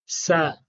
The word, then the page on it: s: Sanskrit